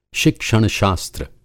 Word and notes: शिक्षण-शास्त्र: Hindi

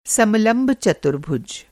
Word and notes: समलंब चतुर्भुज: Hindi

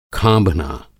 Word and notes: खाँभना: Hindi

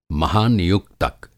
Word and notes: महानियुक्तक: Hindi

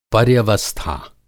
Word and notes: पर्यवस्था: Hindi

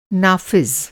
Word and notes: नाफ़िज़: نافذ

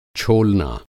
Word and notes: छोलना: Hindi

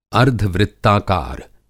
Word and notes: अर्द्धवृत्ताकार: Hindi

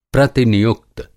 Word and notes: प्रतिनियुक्त: Hindi

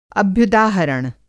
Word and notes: अभ्युदाहरण: Hindi

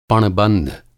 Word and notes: पण-बंध: Hindi

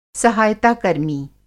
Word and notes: सहायताकर्मी: सहायता-कर्मी

